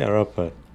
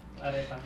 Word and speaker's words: arepa: Um, yes, I did try the, how do you say, arapa. arepa.